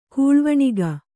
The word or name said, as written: ಕೂಳ್ವಣಿಗ